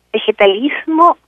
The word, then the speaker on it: vegetalismo: Vegetalismo, right, that's the right pronunciation in Spanish, is a tradition that is a mestizo, mixed-race tradition of curing and healing in the upper Amazon in Peru.